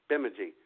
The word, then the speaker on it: Bemidji: And is that how you pronounce that? Bemidji. – Bemidji. Well, I'll tell you what.